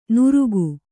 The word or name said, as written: ನುರುಕು